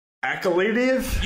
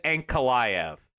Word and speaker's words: Ankalaev: Akhalidiev? – You butchered both of these, by the way. It's Jan Kutalaba against Magomed Ankalaev.